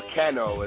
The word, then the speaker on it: Cano: But the only kind of disagreement I had with was the Paulie Malignaggi fight and against Pablo Cesar Cano, or Cano as they were pronouncing it.